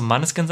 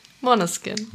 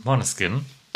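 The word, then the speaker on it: Måneskin: Ich, ich, ich, ich spreche es meistens so englisch falsch aus. So Manneskin sage ich immer. Aber Jana ist immer sehr bemüht um die korrekte Aussprache. Deswegen noch einmal richtig. – Moneskin.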